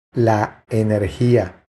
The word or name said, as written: La energia